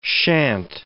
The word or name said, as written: shan't